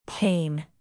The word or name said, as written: pain